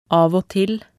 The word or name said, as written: av og til